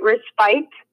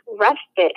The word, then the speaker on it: respite: I would have pronounced it respite, but it's respite.